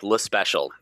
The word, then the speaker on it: Lespecial: So we just say it like Lespecial. So it's kind of like if you were to spell it out, almost just L-U-H special, as Americanized as possible, I guess.